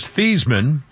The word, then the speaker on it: Theismann: Joe Theismann, whose actual name is Theismann. And they mispronounced it at Notre Dame so he could win the Heisman.